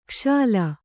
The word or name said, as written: ಕ್ಷಾಲ